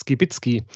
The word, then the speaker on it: Skibicki: Zu Gast bei mir ist heute Professor Dr. Clemens Skibitzky. Hallo Clemens. – Oh, richtig ausgesprochen, super. Ja, Skibitzki ist nicht so leicht.